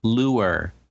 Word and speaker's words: lure: No, lure. It has two syllables. Lure.